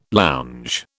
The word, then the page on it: lounge: British English